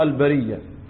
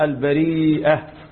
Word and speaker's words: البرية: هو القراءات المتواترة البرية، والعرب تنطقها بالياء وليس بالهمزة، وهنا قراءة شاذة البريئة.